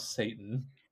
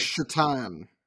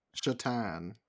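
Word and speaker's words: Šatan: Yeah, Miroslav Satan. Or is it actually Satan, like the pronunciation, or is it like Shatan? – Okay. – It's actually on that page. Pronounced Shatan.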